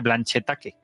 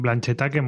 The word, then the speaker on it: Blanchetaque: No sé cómo se llama en francés, así en castellano se dice Blanchetaque, por si alguien lo quiere buscar. – A mí me gusta tu pronunciación, Blanchetaque, mola. – Blanchetaque, ahí, pronunciación.